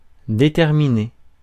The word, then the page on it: déterminé: [de.tɛʁ.mi.ne]; France